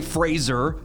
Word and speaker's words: Fraser: I heard him say Fraser a long time ago, so that's how I pronounce it.